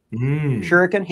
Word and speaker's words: shuriken: But what's interesting here in this still is that Spider-Man has a, I've never known how to pronounce this, Shuriken, the ninja star? – Shuriken? – Shuriken.